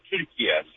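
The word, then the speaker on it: Türkiye: The way Turkish people pronounce it is actually the way it said Turkiye.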